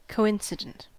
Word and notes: coincident: American English

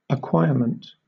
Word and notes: acquirement: British English

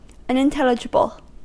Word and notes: unintelligible: American English

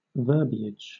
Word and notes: verbiage: British English

